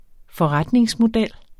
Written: forretningsmodel

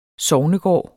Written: sognegård

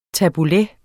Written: tabbouleh

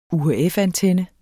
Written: UHF-antenne

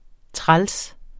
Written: træls

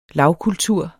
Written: lavkultur

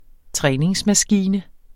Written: træningsmaskine

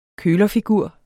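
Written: kølerfigur

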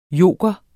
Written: joker

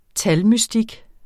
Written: talmystik

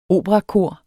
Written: operakor

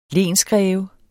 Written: lensgreve

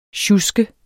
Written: sjusk

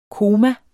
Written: koma

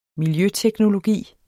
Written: miljøteknologi

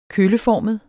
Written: kølleformet

